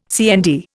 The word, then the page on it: CND: Indian